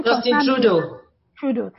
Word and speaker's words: Justin Trudeau: I usually don't get the pronunciation well. – Justin Trudeau. – Thank you.